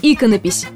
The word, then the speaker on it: иконопись: Каким бы странным ни казался первый вариант – иконопись. Он во всех словарях является единственно верным.